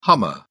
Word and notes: hummer: British English

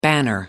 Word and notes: banner: American English

